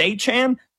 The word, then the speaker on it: Achane: Achane, how do we say his name? We don't know. Nobody knows. Oh, it's Achane.